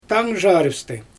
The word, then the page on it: taangʒarɪpstɪ: Xyzyl